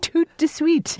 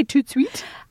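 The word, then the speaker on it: tout de suite: Toot de suite. Dana, say it yourself. Do you just say toot suite? – I think in general the duh is kind of elided in pronunciation.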